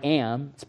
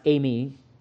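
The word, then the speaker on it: ami (εἰμί): Again, the Greek New Testament here, this is a verb that means I am. It's pronounced ami. It means I am all by itself.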